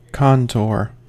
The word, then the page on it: contour: American English